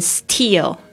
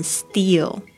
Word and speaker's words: steal: steal 特别注意一下这个 s t e a l 这个 t 的声音，你会发现不是念成 steal，而是念成 steal。